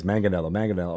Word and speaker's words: Manganiello: I had to practice Manganiello over and over and again to get it right.